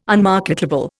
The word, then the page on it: unmarketable: Indian